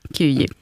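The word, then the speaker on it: Kyjiv: gick över till Kiev, om det nu är ungefär sådär som det uttalas. – Ungefär så, Kiev.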